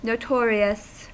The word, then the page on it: notorious: American English; IPA: [noʊ.ˈtɔɹ.i.əs]